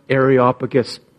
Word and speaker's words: Areopagus: Miriam, you pronounced Areopagus perfectly.